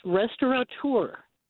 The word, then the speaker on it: restaurateur: I know you folks have been using the word restaurateur, and I was corrected by a friend who edits a seafood magazine that there's no N in that word. It's restaurateur.